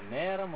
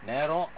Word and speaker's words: நேரம்: இப்ப இந்த நேரம் வந்து ரெண்டு விதமான உச்சரிப்ப பாத்துருக்கலாம். ஒண்ணு வந்து நேரம் அப்படின்னு வச்சிருக்கோம் ஏ போட்டு மேல வருது போடலாம். நேரம் அப்படின்னு சொன்னோம்னா அந்த புரோக்கன் ஓ போட்டுட்டு மேல ஒரு எஸ் தான் போடலாம்.